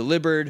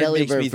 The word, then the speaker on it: Delibird: It maybe should be pronounced such as delivered, where the only difference is the B and V sound. – Right. – So delivered, it just doesn't sound right to me.